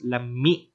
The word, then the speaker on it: lami: Anyway, but you have to remember it's pronounced lami is an I and a hard E sound.